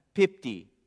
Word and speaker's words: pipti: However, this word used for fails, pipti, is the Greek word. That's how you say it.